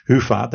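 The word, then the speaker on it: Hyvä: Sounds like a good idea to dedicate that whole episode to Hufa. That's the right pronunciation.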